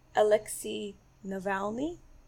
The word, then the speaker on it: Alexei Navalny: And also Alexei Navalny.